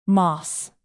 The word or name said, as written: moss